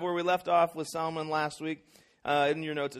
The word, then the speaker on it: Salmon: It is not pronounced like the fish. It's actually pronounced more like the bacterial infection. Salmonella, like salmon.